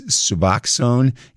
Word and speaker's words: Suboxone: The correct pronunciation is Suboxone, and in case this affects you, I'm going to spell it. It's S-U-B-O-X-O-N-E.